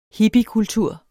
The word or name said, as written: hippiekultur